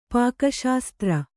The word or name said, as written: ಪಾಕಶಾಸ್ತ್ರ